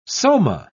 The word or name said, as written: soma